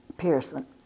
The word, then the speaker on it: Pearson: Wanda Pearson. W-A-N-D-A, initial B, capital P-E-A-R-S-O-N.